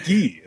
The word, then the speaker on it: Gui: It's Gui. It's actually pronounced Gui. He's French.